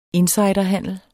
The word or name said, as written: INSIDERHANDEL